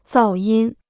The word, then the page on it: 噪音: zào yīn